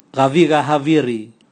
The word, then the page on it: gaviga haviri: Raga